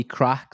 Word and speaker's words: craic: Yeah, so podcast is called Copy Crack, so spelled like the Irish crack, C-R-A-I-C.